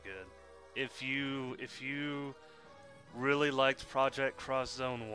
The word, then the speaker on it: Project X Zone: I mean, I was expecting Project Cross Zone 2, which I just realized for like the past couple years I've been saying that wrong. I kept saying Project X Zone. No, it's Project Cross Zone, and I'm like, holy shit, why didn't I see that?